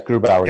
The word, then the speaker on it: Grubauer: No, because if this other guy, again, correct me on his pronunciation of his last name, Philip Grubauer. – Yes, Grubauer.